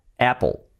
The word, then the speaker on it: Apple: Vale, pues Apple, que es manzana, una palabra súper típica y básica de vocabulario, no es Apple, sino es Apple. Apple. Sería así. Cambia mucho decir Apple a Apple. Apple. Apple, con la L bien fuerte, que fijaos, casi no se pronuncia, pero tiene que estar Apple.